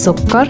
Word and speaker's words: سكر: Wa sukkar. – Sugar. – Sukkar. – And sugar. – Wa sukkar.